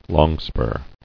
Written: Longspur

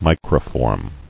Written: Microform

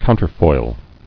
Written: Counterfoil

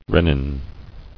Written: Rennin

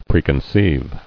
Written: Preconceiving

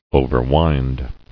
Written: Overwind

